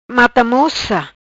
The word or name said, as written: Mata moca